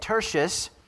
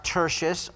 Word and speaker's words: Tertius: So first off, we're going to look at Tertius. His name can be pronounced Tertius or Tertius. Are two different ways that his name can be announced.